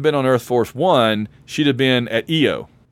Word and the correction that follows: Io: That is the most amazing pronunciation for IO I've ever heard. – IO. – I mean, you only have two letters in that, and they're both vowels, and you pronounce one of them wrong.